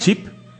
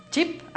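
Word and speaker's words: Ch'p: Tenemos a CH apóstrofe P. – Ok. – No sé cómo se pronuncia eso. – Chip. – Chip, ah, sí.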